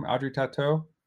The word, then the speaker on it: Audrey Tautou: Audrey Tautou? – Hell yeah.